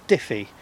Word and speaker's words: Diffey: So my surname is Diffey, and that's spelled D-I-F-F-E-Y.